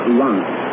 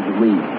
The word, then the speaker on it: Reims: The unconditional surrender by Germany to all the Allies was signed at General Eisenhower's advanced headquarters in the city the French call Lens, but which many Americans know as Reims. To avoid confusion, I'll use the anglicized pronunciation and tell you about the surrender at Reims.